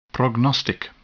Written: prognostic

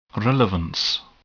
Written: relevance

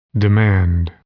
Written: demand

